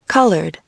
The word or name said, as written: colored